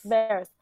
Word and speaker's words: Varys: Varys.